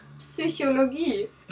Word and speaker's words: Psychologie: Example for the word psychology in German, because we don't have silent sounds, we pronounce the P before S. So it is Psychologie.